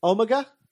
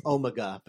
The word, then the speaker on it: Omega: Who I've been yelled at by British listeners to pronounce Omega because that's his name, not Omega, despite the fact that Omega is just Omega with a British accent.